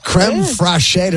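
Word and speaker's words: crème fraîche: Creme fraiche. Did I say that correctly? – And creme chives. Fresh. – Yes, there you go.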